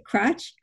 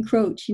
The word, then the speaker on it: Croce: was it Cratch, something like that. But I always think of Robert Croce as the crow. He puts the crow in Croce.